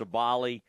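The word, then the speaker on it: Sabally: How do you say her? Sabali? I think it's Sabali.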